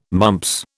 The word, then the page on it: mumps: British English